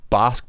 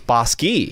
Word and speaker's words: Bosque: This is called Scotia and we've been saying it wrong evidently this whole time. It's not Bosque Brewing, and you wrote down like Bosque. It's Bosque.